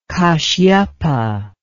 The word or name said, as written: kasyapa